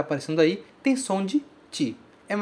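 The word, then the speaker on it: ti (チ): Ele é escrito C-H-I, mas a gente pronuncia ti, sempre ti. Ok? Esse CH no japonês sempre vai ter som de TI.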